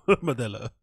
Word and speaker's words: Modelo: But listen to Modella. Uh, um, Modelo.